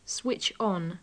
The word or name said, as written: switch on